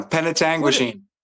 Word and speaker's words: Penetanguishene: P-E-N-E. – Oh, yeah. That's a tough one. Penetanguishene. – What is that? – Well, that's the town that I'm from. Hold on. Let me just. – That's I'm in Penetanguishene.